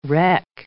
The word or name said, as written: wreck